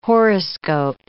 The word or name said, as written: horoscope